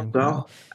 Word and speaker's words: Roald Dahl: like I think you referenced Roald Dahl. Hope I'm pronouncing that. Yeah, Roald Dahl.